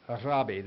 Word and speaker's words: rabbi: The Jews say rabbi. They roll the R and it has a short I on the end.